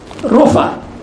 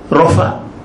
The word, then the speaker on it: Rapha: In other words, God said, I am the Lord, Rapha. In English it's written as Rapha. What's pronounced in Hebrew is Rofa.